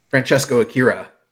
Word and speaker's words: Francesco Akira: I was impressed with TJP and probably won't butcher his name, Francisca. – Francesco Akira.